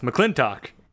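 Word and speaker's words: McLintock: Kelsey has informed me that apparently McClintock is pronounced McClintock.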